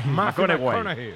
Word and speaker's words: McConaughey: Hay que ir corrigiendo la pronunciación. – McConaughey. McConaughey.